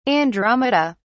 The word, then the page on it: andromeda: British English